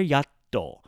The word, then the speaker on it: やっと: Now, yatto is something that has to, when you pronounce it, you need that chitsai tsu, the little tiny pause between the ya and the to. There's a little space there, yatto, when you pronounce it, which is very, very important.